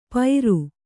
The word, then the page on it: ಪೈರು: pairu